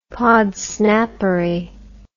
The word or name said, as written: podsnappery